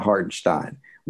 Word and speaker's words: Hartenstein: If they are unable to bring in somebody, if they don't re-sign somebody like Isaiah Hartenstein, and we went over how to pronounce that. – Yeah, it's Stein. – You're right. – Okay. Yeah. Isaiah Hartenstein.